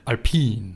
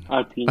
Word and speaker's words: Alpine: Wir haben noch äh, das erste Fotos von, wie haben wir jetzt gesagt, spricht man es aus? – Alpine. – Alpine.